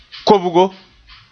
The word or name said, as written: kobgo